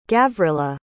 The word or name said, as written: gavrilla